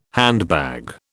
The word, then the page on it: handbag: British English